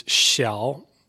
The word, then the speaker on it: Xiao: 为一个品牌 as a brand 它有很多其他的家居就是家里面用的一些比如说啊蓝牙音箱水杯然后就是这种智能家居的一些产品做得很好对比如说电动牙刷现在小米就是什么东西都卖不仅仅只是卖手机它的手机也不像以前那样子受欢迎了 So Xiaomi sells a lot of other stuff not just phones So what about this pronunciation then Xiao So small So small in Chinese is Xiao And that's the first part of Xiaomi, the word Xiao. Mm-hmm. So you've got the falling, rising tone, the third tone, Xiao.